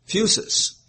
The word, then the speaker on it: phusis: Now, the word nature means, or is from the Greek phusis, P-H-U-S-I-S, is how I think we would probably pronounce it, phusis, P-H-U-S-I-S.